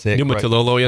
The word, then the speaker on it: Niumatalolo: Yes, Niumatalolo.